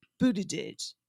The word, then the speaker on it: Buttigieg: Yes, you have Pete Buttigieg.